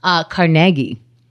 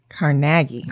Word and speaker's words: Carnegie: Carnegie. That apparently is how you say it. – Carnegie. – Carnegie. Yep. – Carnegie. – Instead of Carnegie.